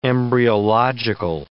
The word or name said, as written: embryological